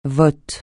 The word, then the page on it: vote: francais